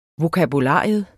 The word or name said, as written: vokabular